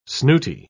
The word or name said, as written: snooty